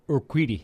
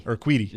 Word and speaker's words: Urquidy: Urquidy? – Urquidy. – Urquidy.